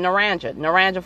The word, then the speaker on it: Naranja: Like, if you Google Naranja, and yes, it's spelled like Naranja, N-A-R-A-N-J-A, like you would think it would be naranja, because that's how it is supposed to be pronounced in Spanish. And in if you do like a Google Maps, and it will say you're on your way to Naranja, and it's like no, just like regionally, there's pronunciations that are very specific for that area.